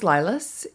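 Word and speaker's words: Lylas: So my name is Lilas.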